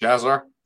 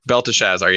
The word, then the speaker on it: Belteshazzar: Belteshazzar. – Belteshazzar.